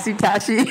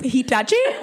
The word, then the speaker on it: Hitachi: You be using the Hitachi for him. – I believe it's pronounced Hitachi. Hitachi magic wand.